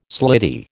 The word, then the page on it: slitty: American English